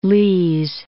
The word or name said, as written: Lee's Summit